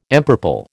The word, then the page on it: empurple: American English